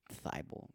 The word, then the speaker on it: Thybulle: Matisse Thibel thibal, bro. It's like thyroid. It's like a, a gland in your body.